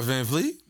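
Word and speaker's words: VanVleet: You talking about Van Vliet? – Van Vliet, I'm sorry.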